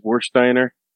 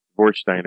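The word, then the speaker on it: Warsteiner: Yeah, this is Wursteiner, Oktoberfest. – Wouldn't it be pronounced, aren't the W's pronounced with a V? – Wursteiner.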